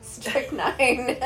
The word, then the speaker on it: strychnine: Strychnine. – Isn't that how you say it? – Strychnine. It's rap. – Strychnine. Yeah, I know. – It's not an extra syllable.